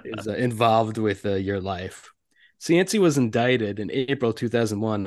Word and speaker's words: Cianci: Cianci.